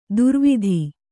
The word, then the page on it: ದುರ್ವಿಧಿ: durvidhi